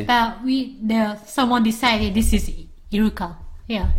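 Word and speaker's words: Iruka (イルカ): How do you say that? Iruka? – Iruka. – Iruka. But Iruka sounds like orca, like English orca.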